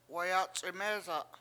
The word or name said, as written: wayAc71mEEsa7